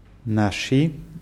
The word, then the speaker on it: наши: Наши Друзья очень хорошо играют в теннис. – Gut, und die Betonung bei наши ist auf A.